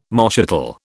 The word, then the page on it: moschatel: British English